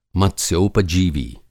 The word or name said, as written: मत्स्योपजीवी